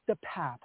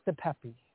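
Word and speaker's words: DePape: For starter, the district attorney says the suspect's name is actually pronounced David DePap, not DePepe.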